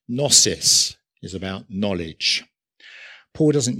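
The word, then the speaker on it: gnosis (γνῶσις): It's got a silent G at the beginning, so you might read it as gnosis, but don't. It's gnosis.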